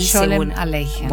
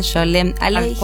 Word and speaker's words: Sholem Aleichem: Sholem Aleichem. – Bueno, no lo sabía pronunciar bien, Sholem Aleichem.